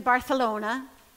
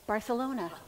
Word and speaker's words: Barcelona: We went to Barcelona. That's how they say it. Yeah? Because once, a long time ago, a king had a lisp and that was it. And everybody had to say Barcelona.